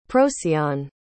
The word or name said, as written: procyon